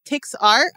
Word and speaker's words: TixR: There's a website called TixR. I'm pretty sure that's how you say it. It's T-I-X-R.